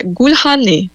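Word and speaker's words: Gülhane: Aí eu vou falar aqui, é Gulhane, acredito que seja isso. Talvez até mais aberto, Gulhane. E o G não é pronunciado, mas eu, sinceramente, a gente tem que fazer aqui uma pressão para falar bonitinho.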